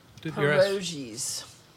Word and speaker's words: pierogi: P-E-R. I'm not even sure I'm saying it right. Let me see if we can get Siri to say it. Pierogies. – Pierogi. – Yeah, it's that Polish dish. Pierogi. Yeah, I think that's the way you say it.